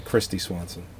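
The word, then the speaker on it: Kristy Swanson: Kirstie Swanson. I mispronounced it. – Oh, okay, Christy Swanson.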